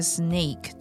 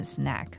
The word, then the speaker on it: snake: snake 的发音不要念成 snack、